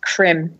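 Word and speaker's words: Creme: Yeah, just to verify this, I know it's kind of a tangent, but yeah, Benjamin Krim, I think it's pronounced Krim. – Krim. – Krim. You know, with a little accent.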